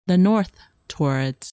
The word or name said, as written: North Taurids